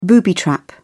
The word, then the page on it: booby-trap: British English